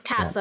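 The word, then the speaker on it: Kassa: His real name is what? Cass. It's similar Casa. – To Cassa. – Casa. – It's Cassa. Yeah.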